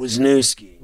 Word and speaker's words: Wisniewski: With the signing of Wyniski, is that how you pronounce it? – Wisniewski. – Wisniewski.